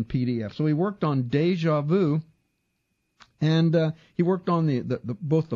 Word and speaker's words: DjVu: Now, it's abbreviated DJVU, Deja Vu is how it's pronounced.